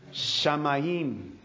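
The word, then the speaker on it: Shamayim (שמים): Shamayim.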